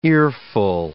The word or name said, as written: earful